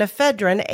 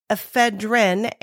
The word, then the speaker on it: ephedrine: In the late 70s and early 80s, companies were producing the lookalikes Les mentioned using primarily caffeine and ephedrine, HCL. You'll hear it pronounced both as ephedrine and ephedrine. Same stuff.